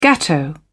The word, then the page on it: gateau: British English